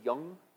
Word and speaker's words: Jung: Brenda said it was Young. I think it's Jung, J-U-N-G, but she said she probably changed the pronunciation of her name after this psychotherapist, Mandy Young.